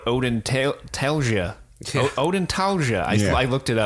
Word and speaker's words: odontalgia: And just like he says, it's spelled, if you want to know, all you spelling bee people, it's spelled O-D-O-N-T-A-L-G-I-A. – Ah, Odin Talgia.